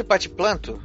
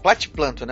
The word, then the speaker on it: Platiplanto: Os cavalinhos de platiplanto. Platiplanto, né? Tu falando certo, né? – Platiplanto, isso.